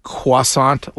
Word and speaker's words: croissant: Here's a 617 text that says, Greg, if you pronounce croissant like that when ordering, you're a hardo. Well, no, I mean, that's what you're supposed to say. – That's how it's pronounced.